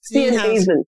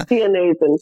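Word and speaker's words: Steenhuisen: I'd like to talk a little bit about the Democratic Alliance, and you know the head of it is John Steinhauser. Is that how you pronounce it? – Steinhausen. – Yeah. – Steinhausen.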